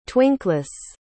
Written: twinkless

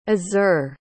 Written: azur

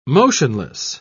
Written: motionless

